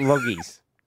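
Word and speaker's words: Logies: Loggies. – It's loggies. We've discussed this. – Loggies. – Loggies. Okay, yeah, so that's how it's pronounced.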